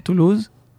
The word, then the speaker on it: Toulouse: Toulouse.